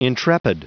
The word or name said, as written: intrepid